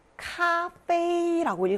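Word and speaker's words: 咖啡: 커피는 중국어로 카페이라고 읽어요. 이 아이 발음 예외 발음입니다. 1성이니까 카페이, 카페이 이렇게 읽으시면 됩니다.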